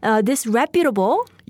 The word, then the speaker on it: disreputable: I'll spell it. D-I-S-R-E-P-U-T-A-B-L-E. Disreputable. – 네, 발음에 주의하세요. 스트레스가 두 번째 오는군요.